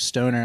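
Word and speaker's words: Stohner: Yeah, my name is Scott Stoner. It's not spelled the way it's pronounced, at least. I'm a substance use counselor, so there's a silent H in there. It's S-T-O-H-N-E-R, but my last name's Stoner, and I'm technically a drug counselor to pay the bills.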